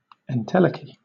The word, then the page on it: entelechy: British English